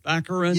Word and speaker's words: Baccarin: Baccarin? – Baccarin.